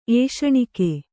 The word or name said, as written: ಏಷಣಿಕೆ